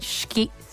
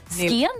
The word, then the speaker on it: Scion: A 2005 Toyota, I'm not going to pronounce this right, Skien? Skion? – Needless again. S-C-I-O-N.